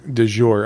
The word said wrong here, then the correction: du jour: Du jour. It's du, not de.